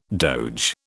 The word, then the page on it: doge: British English